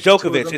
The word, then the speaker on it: Djokovic: Dijakovic. It's, it's Djokovic. It's Djokovic.